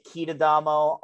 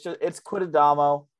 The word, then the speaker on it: Quitadamo: It's spelled exactly how it sounds. There are no frills, no fancy accents to it, anything like that. And I'd get like Quitadamo all the time, Quitadamo. But it's Quitadamo, Tim Quitadamo.